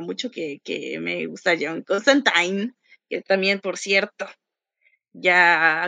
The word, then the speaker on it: Constantine: Constantine, como, sí, sí, como wine, como Constantine. – wine. – Rhymes with wine.